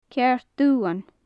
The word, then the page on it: ceàrd-dubhan: gaelic